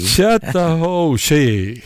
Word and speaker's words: Chattahoochee: Alan Jackson, hur uttalar man Chattahoochee? – Chattahoochee. – Ja, okej. Tack för det.